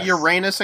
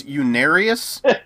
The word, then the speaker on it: Unarius: Whenever I look at it, I'm, like, dyslexic and everything, so I automatically see Uranus. So I always want to say Uranus Academy of Science, but it's Unarius.